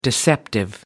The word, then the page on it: deceptive: American English